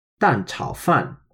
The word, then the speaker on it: 蛋炒饭: So that's pronounced 蛋炒饭. So that's a fourth tone, third tone, and another fourth tone. So that's the first fourth tone is D-A-N, 蛋, which means egg.